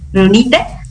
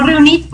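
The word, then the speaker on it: Riunite: Uno es el nombre, el nombre de la marca es Runite, no Reunit, porque luego lo pueden escuchar así, pero el nombre correcto y la pronunciación correcta es Runite.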